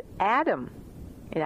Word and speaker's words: Adamah: which is Adama, A-D-A-M-A-H. And of course, we know that the first man, Adam, was indeed made from Adama, the ground of the earth.